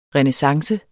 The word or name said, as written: renaissance